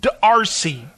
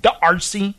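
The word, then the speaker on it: D'Arcy: It's Darcy. It's D apostrophe A R C Y. So do I pronounce it Darcy? – Yes. Yeah, that's what I thought. That's exactly how you It's pretty it.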